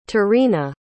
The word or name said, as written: terena